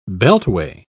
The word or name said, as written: beltway